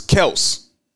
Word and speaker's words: Kelce: By the way, did you see the story with Travis Kelsey? He was on a podcast. You know we've been saying his last name wrong forever, right? – What is his last name? – It's Travis Kelce.